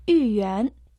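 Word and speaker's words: Юй-Юань (豫园): Юй-Юань, иероглиф «Ю», произносится в четвертом доне. Переводится как «благополучие», «счастье». А иероглиф «Юань» – второй дон. Это сад, парк.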